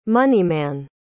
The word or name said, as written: moneyman